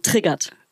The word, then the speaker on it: triggert: Aber auch so deutsch aussprechen, triggert. Triggert. – Triggert. – Ja, triggert. – Mit AT hinten. Triggert.